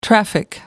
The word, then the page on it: traffic: American English